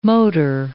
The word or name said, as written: motor